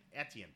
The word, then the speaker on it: Etienne: Eskian? – Etienne, pardon me.